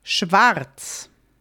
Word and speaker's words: schwarz: Also, sometimes Austrians and German, like German-speaking people, we will, in this case, just not say the R. There is an R in this letter. You spell it, the whole word schwarz, black, is spelled S-C-H. W-A-R-Z. So there's an R at the very end or the second to last word. And very often we'll just leave it out.